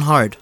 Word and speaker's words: Heart: My name is Jonathan Hard. – Could you spell that, please? – H-E-A-R-T.